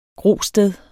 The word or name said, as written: GROSTEDER